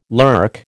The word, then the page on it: lurk: American English